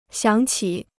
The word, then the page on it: 响起: xiǎng qǐ